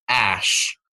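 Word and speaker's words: Æ: The A-E is actually pronounced Ash.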